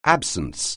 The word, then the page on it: absence: anglais